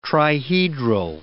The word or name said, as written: trihedral